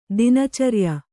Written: ದಿನಚರಿಯ

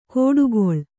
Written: ಕೋಡುಗೊಳ್

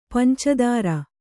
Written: ಪಂಚಧಾರೆ

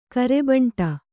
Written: ಗೋರಿಬೇಂಟೆ

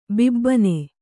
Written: ಬಾಬೂನ

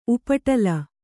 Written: ಉಪಟಲ

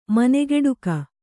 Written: ಮನೆಗೆಡುಕ

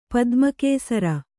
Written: ಪದ್ಮಕೇಸರಿ

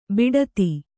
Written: ಬೊಟ್ಟೆತ್ತು

